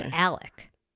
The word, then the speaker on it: Alex: I think it's Alec. But it's spelled Alex, but everyone keeps saying Alec, so I'm going to go with Alec.